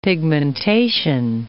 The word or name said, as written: pigmentation